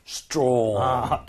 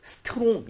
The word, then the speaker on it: strong: Strong. – 더 이상 strong 이게 아니라 strong. 이렇게 R 발음을 좀 신경 써 주시면 더 좋을 것 같아요.